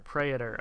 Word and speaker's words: Praetor: Praetor, okay.